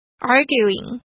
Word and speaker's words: arguing: 大家要注意这边的 u 念作 u arguing。